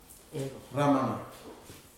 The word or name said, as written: ramala